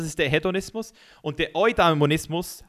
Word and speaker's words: Eudaimonismus: Hast du schon mal vom Eudena, Eudani, Eudanismus gehört? Sorry, Mann, ich kann es gar nicht mehr aussprechen. – Euda, Euda, ich habe ein Video darüber gemacht und ich kann es immer noch nicht aussprechen. Ah, Eudaimonismus. Eudaimonismus. Das ist der stoische Weg, der Eudaimonismus.